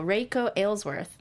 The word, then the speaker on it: Reiko Aylesworth: Reiko Aylesworth.